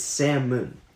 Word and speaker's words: salmon: No, it's salmon. – It's salmon. – Salmon. – Salmon. The L is silent.